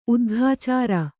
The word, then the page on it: ಉದ್ಘಾಚಾರ: Kannada